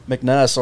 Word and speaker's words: McNeese: The At first I was saying it wrong, then you corrected Mc, me. McNeese? – Yeah, McNeese. Then McNess, or however you want to pronounce it.